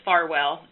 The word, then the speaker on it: Farwell: No, it's Farwell, F-A-R-W-E-L-L.